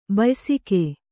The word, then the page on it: ಬಯಸಿಕೆ: bayasike